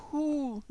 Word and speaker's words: who: Le who, WHO, on ne prononce pas le W, on l'ignore, donc on atterrit sur le H.